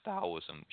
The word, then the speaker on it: Taoism: W-E-I. So it's Wu-Wei. And Wu-Wei is basically a concept in Taoism. It's actually, it does say Taoism with a T, but it's actually pronounced Taoism, should be with a D.